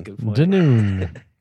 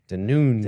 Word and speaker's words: Dunoon: Anyway, if I pronounced it right, what is it called? Well, we're not going to get it from a text. We'll just say it wrong again. – Dunoon. – Dunoon. – Dunoon is what I said.